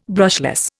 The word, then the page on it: brushless: Indian